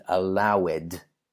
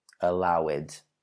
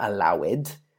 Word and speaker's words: allowed: Okay, now the way I often hear it, as you've just heard, is allowed. Allowed. So, the correct pronunciation is actually allowed, not allowed, but allowed.